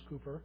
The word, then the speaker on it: Cowper: This is a line from a song written by William Cowper, spelt Cowper, C-O-W-P-E-R, but pronounced Cooper.